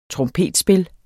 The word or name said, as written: trompetspil